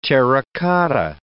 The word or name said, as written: terra-cotta